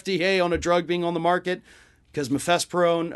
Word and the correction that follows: mifepristone: uh uh, uh, uh, I forgot how to say it. I'm so sorry. Um, mifepristone, mifepristone, yeah, mifepristone has been on the market for 20 years.